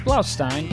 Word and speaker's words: Blaustein: It's Blaustein.